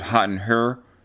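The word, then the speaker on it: Hot in Herre: Then, you know, like I said, it got, for me, it's got Better and More Fun, Hot in Here. And I can't say, I said it wrong, it's Hot in Her.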